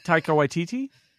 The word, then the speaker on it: Taika Waititi: Taika Waititi? – Yes!